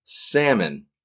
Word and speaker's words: salmon: The second item here of food is salmon. – The L is silent, friends. You can look it up. The L is silent. It's not salmon. It's salmon.